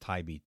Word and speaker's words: Taibbi: Episode 1386, Matt, I think it's pronounced Tibi, T-A-I-B-B-I, November...